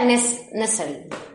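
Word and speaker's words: Nestle: Que yo no sabía pronunciar eso, porque es que se escribe, es muy gracioso, se escribe Joan Nessel. Pero es que Joan Nessel es un yogur. Entonces, digo... Pero se pronuncia Nessel.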